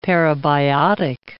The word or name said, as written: parabiotic